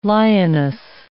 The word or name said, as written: lioness